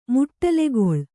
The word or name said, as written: ಮಡಲ್ಗೊಳ್